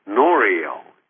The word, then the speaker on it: Norio: First name is N-O-R-I-O, Norio. N-O-R-I-O, and the last name is spelled H-A-Y-A-K-A-W-A. H-A-Y-A-K-A-W-A, and it's pronounced Norio Hayakawa.